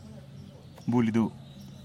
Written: bulidu

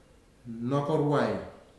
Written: Nakarwai

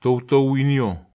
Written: tow tow inio

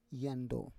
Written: yando

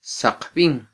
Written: saqbin

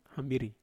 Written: hambiri

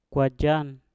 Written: kwajan

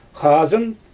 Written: xazɯn

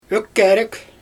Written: ökkErEk

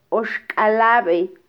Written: oxkalabe

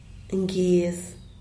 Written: ngyez

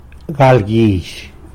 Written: galguizh

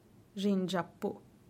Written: dxingapu

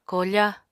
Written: koofa

